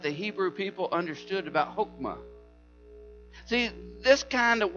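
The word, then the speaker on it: chokmah (חָכְמָה): In the Hebrew language, it is roughly pronounced chokmah. Chokmah. So say that with me.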